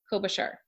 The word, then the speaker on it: Klobuchar: So there's another female, another woman that we want to talk about, and that's Amy Klobuchar. Is that how you say it? – It is. Klobuchar.